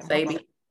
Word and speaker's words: Sebi: I never know how to pronounce his name. – Sebi.